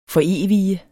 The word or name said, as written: forevige